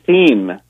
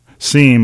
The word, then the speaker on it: SEAM: We say SEAM. – SEAM, like unseemly. – Yeah. Yes, or like, that's how things seem.